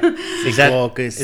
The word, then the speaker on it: Secaucus: Secaucus. – Is that how you pronounce it? – Yeah.